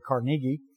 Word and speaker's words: Carnegie: We always say his name wrong. It's actually Carnegie.